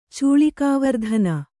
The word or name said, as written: ಚೂಳಿಕಾವರ್ಧನ